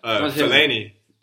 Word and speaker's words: Fellaini: Fellaini.